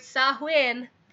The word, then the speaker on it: Samhain: Enjoy your witching hour and make sure that you respect the Ishii and you celebrate Samhain properly.